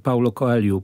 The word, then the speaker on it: Paulo Coelho: Ja się na przykład zdziwiłem, że wymowa tych końcowych o w nazwiskach, w imionach tam jest wymawiana jako u, prawda? Czyli Paulo Coelho powinniśmy mówić, tam przynajmniej gdybyśmy byli Brazylijczykami.